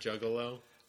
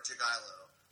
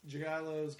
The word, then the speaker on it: Jagielo: I've heard that the G is definitely pronounced. So it's either Jagielo or Jagielo.